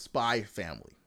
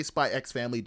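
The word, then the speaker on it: Spy × Family: And for those of you who don't know, there are animes that have X's and then the X is silent. So it's Spy Family. You could say Spy X Family.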